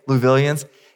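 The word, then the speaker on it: Louisvillians: So, my wife and I are proud to be Louvillians. That's how you say it, Louvillians. You say it Louisville. You just like drag it out, you know? That's how you know if you're a local.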